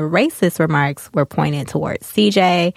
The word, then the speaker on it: Ceejai: On this particular episode, on the finale that we're talking about, CJ, which is spelled CEEJAI.